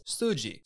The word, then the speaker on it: suji: Today's Lessons is going to be the Japanese word for muscle, and that is suji. Suji is spelled S-U-J-I